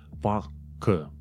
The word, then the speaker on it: Parker: Da, Matt Parker. Dar de fapt, uh, Matt Parker, faptul că are acele eruri în cuvânt, nu se citește Parker, ci, ci se citește Parker, Parker, Parker. Știi, un fel de r din la gătuit, așa.